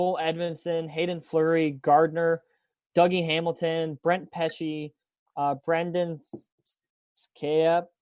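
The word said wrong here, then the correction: Skjei: I don't know how to pronounce that. – Brady Shea. – Brady Shea, that's it.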